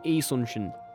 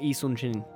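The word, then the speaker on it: Yi Sun-shin: In Korea, the Y is actually quite soft, from what I know. I don't know much about the Korean language or Korean pronunciation itself, so it's actually the way to correctly pronounce this admiral's name is Yi Sun Shin, I believe, or Yi Sun Shin.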